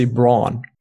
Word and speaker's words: Braun: we would say brawn brawn but